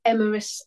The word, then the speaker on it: Emrys: There is a chap by the name of Emeris Sky, and his name is spelled Emrys Sky with an E on the end,